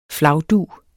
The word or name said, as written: flagdug